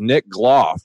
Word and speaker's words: Nick Gloff: And with us today on the line is Nick Gloff.